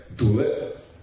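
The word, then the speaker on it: Dullea: U svakom slučaju, čak recimo glavni glumac, Kir Dulea, ili Dulea, ja ne znam kako se to izgovara. – Dulea.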